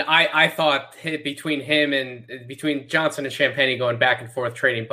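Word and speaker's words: Champagnie: him going head to head with Champagny, am I even pronouncing his name right? Is it Champagny or is it Sham? I have no idea. – No, you're pronouncing it right. Champagny. Yep.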